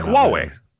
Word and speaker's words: Huy: Wait, what's HUY? I played it here. I just don't say names. Yeah. Okay. That's what I said then. – Yeah. You said it right. – All right. Well, one of those. – Like Huawei. – There. You know what? Yes. And spelled differently, right? – Yes.